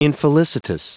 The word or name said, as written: infelicitous